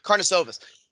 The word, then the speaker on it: Karnisovas: Karnisovas.